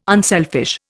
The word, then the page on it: unselfish: Indian